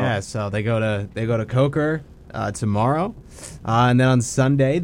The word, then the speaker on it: Coker: But tomorrow at 10 a.m., they are playing a school called, I hope I'm pronouncing this right, Coker? – Yes. That is how I would pronounce that, Coker.